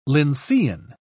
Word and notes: lyncean: British English

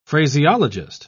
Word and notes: phraseologist: British English

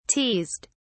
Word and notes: teased: British English

